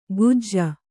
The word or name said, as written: ಕುಜ್ಜ